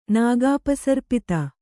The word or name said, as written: ನಾಗಾಪಸರ್ಪಿತ